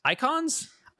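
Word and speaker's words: Eikons: And in 16, the summons are called icons. – Icons, Icons. – Icons. – It's spelled wrong, of course. – Yes, you know. – E-I-K-O-N.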